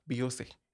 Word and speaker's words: Biosse: BIOSSE.